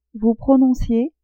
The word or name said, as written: vous prononciez